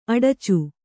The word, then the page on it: ಅಡಚು: aḍacu